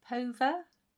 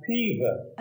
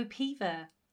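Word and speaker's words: Peover: Well, in Cheshire, it's pronounced Pever. – Oh, Pever.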